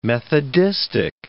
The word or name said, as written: methodistic